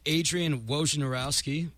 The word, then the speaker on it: Wojnarowski: According to Adrian Wojnarowski, that's how you say it, Woj bombs.